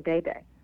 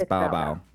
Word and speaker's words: Bao Bao: Well, it looks like, I thought it was Bao Bao, but you keep saying Bay Bay. – I think it's Bao Bao.